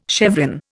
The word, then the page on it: chevron: Indian